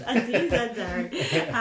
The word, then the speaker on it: Aziz Ansari: am I saying his name right? I what are you saying? Anzi, Anzi, Aziz Aziz Aziz Ansari.